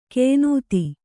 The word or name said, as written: ಕೆಯ್ನೂತಿ